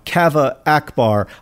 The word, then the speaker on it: Kaveh Akbar: On the most recent episode of the pod, you read a poem from, I'm going to say his name right now, Kaveh Akbar. I happened to go to grad school with Kaveh and was in a few classes with him. His name, you have been pronouncing it wrong, John. You have been pronouncing it wrong this whole time, even though you are a big fan of his.